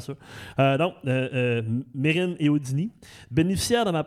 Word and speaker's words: Mesrine: mais je me rappelle plus ça vient de où, mais que c'était pas Mesrine. C'est Mérine.